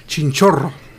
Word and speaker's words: Chinchorro: That was the Chinchorro people in Chile, by the way. – Oh, no, you gotta roll your R's better. Chinchorro.